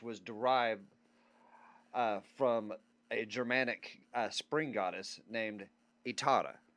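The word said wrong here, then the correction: Eostre: Okay. No, I like the name. – No, I'm sorry. Estra. Okay. Estra. I'm sorry. I mispronounced that.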